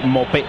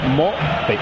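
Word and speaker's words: Maupay: Mopé sería la pronunciación correcta. Mopé.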